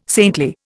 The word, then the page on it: saintly: Indian